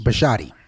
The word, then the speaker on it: Bisciotti: Did I say that right? Biscotti or Bisciotti? – Bisciotti. – Bisciotti, yeah. Bisciotti is going to hold firm where he's going to be really tested is if the Ravens, say, get to a Super Bowl.